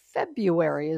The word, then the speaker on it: February: And boy, we're zooming right along into the month of February. How come we never pronounce that R? February is the way I say it.